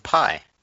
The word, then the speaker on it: Pi: It's Life of Pi. – What the fuck do algebra kids need to watch Life of Pi for? – It's spelt P-I, as in pie.